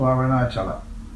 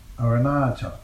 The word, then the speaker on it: Arunachala: Various meanings are assigned to Arunachala, the name of the sacred hill. The third and last vowels are long. Accent being mainly in the third, Arunachala.